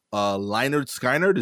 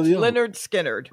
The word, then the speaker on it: Lynyrd Skynyrd: In this week's Florida being Florida segment, Florida man, wanted for stealing a trove of, Lynyrd Skynyrd. Is that how you say it? – No, that's Lynyrd Skynyrd.